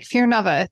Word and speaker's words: Fearvana: If you think of Nirvana and I'm like, Fear Nova, to say it correctly.